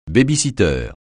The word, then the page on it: baby-foot: francais